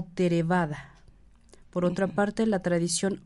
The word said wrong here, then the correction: Theravada: Dentro del budismo, Theravada. Theravada. Theravada. Que lo diga ella. Pronunciamos Theravada. Ok.